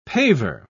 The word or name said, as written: paver